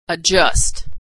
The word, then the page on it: adjust: dzh